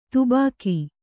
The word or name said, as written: ಧಬಕ